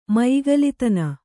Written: ಮಯಿಗಲಿತನ

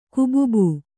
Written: ಕುಬುಬು

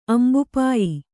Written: ಅಂಬುಪಾಯಿ

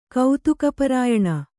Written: ಕೌತುಕಪರಾಯಣ

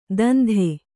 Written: ಧಂಧೆ

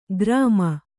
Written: ಗ್ರಾಮ

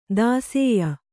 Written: ದಾಸೇಯ

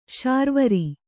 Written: ಶಾರ್ವರಿ

